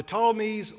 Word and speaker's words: Ptolemies: The Ptolemies was that first one, it starts with the P. I don't know why the P is silent.